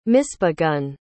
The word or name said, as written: misbegun